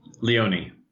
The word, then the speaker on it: Leone: Is it Leone? – Leone.